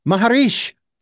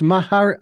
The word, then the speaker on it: Maheshri: Jagdish Maheshri, thank you for joining us here on the program. – Thank you. – And I believe I mispronounced your last name. That's Maheshri.